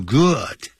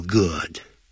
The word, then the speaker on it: good: It makes them feel good. 那个 good 也不要念成 good, 要念成 good, good. 你在查词典的时候可以看得到它的音标的符号像欧米伽倒过来写的那个样子。